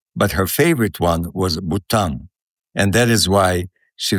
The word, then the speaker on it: Bhutan: The name of the country is Bhutan. It's spelled with H, B-H-U-T-A-N.